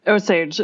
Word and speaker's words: Osage: Osage oranges? – Osage. It's the same Osage. As the tribal nation.